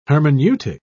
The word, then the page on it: hermeneutic: British English